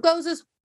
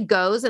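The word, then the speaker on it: Gose: It is pronounced Goza. Some people say goes, and like, that's totally, I mean, honestly, most people don't know how to say Goza, but I'm like 95% sure Goza, like it should have two syllables in there.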